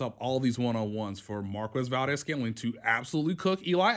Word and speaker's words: Marquez: All right, well, the name is Marquez, by the way.